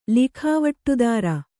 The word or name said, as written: ಲಿಖಾವಟ್ಟುದಾರ